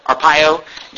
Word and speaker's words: Arpaio: This was a difficult decision, Sheriff Joe Arpaio said, or is that, am I pronouncing it right? Arpaio.